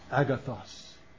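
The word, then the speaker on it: agathos (ἀγαθός): There is another one that is pronounced agathos.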